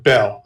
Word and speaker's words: Beale: It's spelled Beale, and they pronounce it Bell.